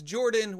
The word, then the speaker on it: Jorphdan: My name is Jordan with a silent PH in the middle, and I am joined always by my wonderful co-host, Sir Lucian, over at Sir Lucian Gaming.